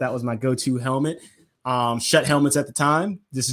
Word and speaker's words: Schutt: I believe the pronunciation is shut. I could be wrong. I could have been pronouncing this wrong for my entire life, but I always believed they were shut helmets. S-C-H-U-T-T.